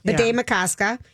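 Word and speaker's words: Bde Maka Ska: Not how do we say it? – Bidet Makaska. – There we go. – Bidet Makaska.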